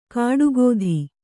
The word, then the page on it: ಕಾಡುಗೋಧಿ: kāḍu gōdhi